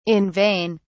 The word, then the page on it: in vain: British English